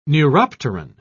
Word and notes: neuropteran: British English

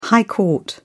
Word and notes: high court: British English

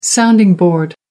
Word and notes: sounding board: American English

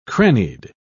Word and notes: crannied: British English

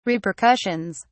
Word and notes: repercussions: British English

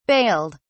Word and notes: bailed: British English

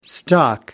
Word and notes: stock up: American English

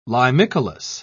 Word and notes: limicolous: British English

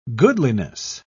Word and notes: goodliness: British English

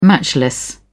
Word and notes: matchless: British English

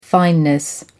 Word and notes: fineness: American English

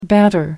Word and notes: banter: American English

